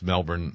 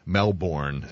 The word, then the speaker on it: Melbourne: I can tell you've at least spent some time in Australia because, you know, it's Melbourne, not Melbourne, as a lot of Americans like to pronounce it.